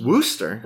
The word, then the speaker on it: Worcester: It's the Worcester County. – Worcester? – Worcester County.